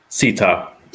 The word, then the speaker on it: Sitha: Is it Sitha? Am I saying that right? – Sita. – Dang it. Yeah, the H is silent.